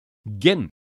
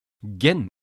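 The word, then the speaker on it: gin: 出だしのゲン、お酒のジンも同じスペリングですが、この単語はゲンと発音します。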